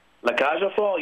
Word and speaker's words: La Cage aux Folles: You're appearing in La Cage aux Folles. Did I say it right? – La Cage aux Folles,